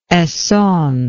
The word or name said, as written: Essonne